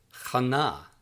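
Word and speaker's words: Chana (חנה): Word 16, Hannah, is pronounced Chana, Chana, Chana.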